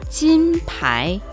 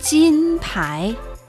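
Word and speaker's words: Jin Pai (金牌): Jin Pai. Jin Pai.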